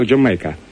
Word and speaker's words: Do You Make Her: Вот такая вот шуточка была, вот на этом они взяли, так вот вынесли ее в название, вот из этой шутки, такое вот написание «Do you make her?», которое произносится как «Jamaica».